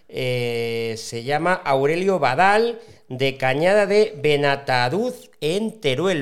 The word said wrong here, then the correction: Benatanduz: Pues bueno, no lo has dicho muy bien porque es Cañada de Benatanduz.